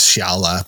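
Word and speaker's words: Xiala: There's a character who on the audiobook, her name is Shiala, but it's spelled with an X, which is very Mayan, very, very Mexican.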